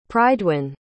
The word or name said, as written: prydwyn